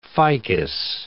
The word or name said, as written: ficus